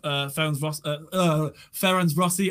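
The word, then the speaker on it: Ferencváros: How did I? Ferencvaros. That's it. There we go. That's how I wrote it down phonetically.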